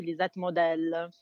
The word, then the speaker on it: Lisette Model: Certo, allora la mia riflessione, sono giunta a questa conclusione che si pronunci Lisette Model, perché in realtà Model non è il cognome con cui nasce quest'artista, che invece viene da una famiglia austriaca e quindi si chiama Seibert di cognome,